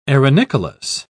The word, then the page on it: arenicolous: British English